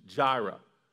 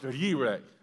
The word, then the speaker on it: Jireh: J-I-R-E-H, Jireh. And I believe in Hebrew, it's pronounced Yireh, and they roll the R a little bit, Yireh. And I don't think I'm pronouncing it just right, but it's not Jireh like you and I would say, okay?